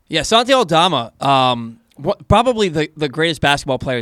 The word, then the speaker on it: Santi Aldama: This week, the guys caught up with Grizzlies rookie and former Loyola star Santi Aldama. I believe I said that correctly. – Santi Aldama.